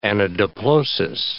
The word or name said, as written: anadiplosis